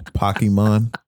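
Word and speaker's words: Pokémon: It's pronounced Pokemon.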